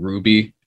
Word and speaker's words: RWBY: Do you guys remember the show from ages ago called Ruby, and it was spelled like R-W-B-Y? – Yeah.